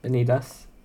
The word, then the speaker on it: Vanitas: The Case Study of Vanitas. – Yeah, you said it right. Vanitas.